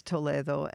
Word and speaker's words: Toledo: I did know that the story from the beginning would end in the Sephardic Museum in the city of Toledo, or Toledo in the U.S. people might pronounce it Toledo, so you know what I'm talking about. But the Spanish city is Toledo, and I have been there many times and have been to the Sephardic Museum, which was once a 14th century synagogue.